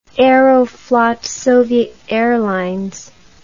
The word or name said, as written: aeroflot soviet airlines